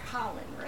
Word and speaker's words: Khalen: Now it's Khalen, right? – Yes, ma'am. – Even though it's spelled differently.